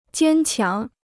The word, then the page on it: 坚强: jiān qiáng